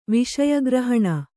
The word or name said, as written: ವಿಷಯಗ್ರಹಣ